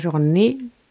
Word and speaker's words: Ronny: Och jag tror att jag uttalade ditt namn fel. Jag uttalade det Ronny, men det är Ronny, hörde jag. Hur säger du? – Eh, Ronny. Lite mittemellan kanske, Ronny Sally.